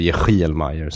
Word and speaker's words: Yehiel Mayer: Och han hette då Yehiel Mayer, eller Yehiel Mayer som det uttalas.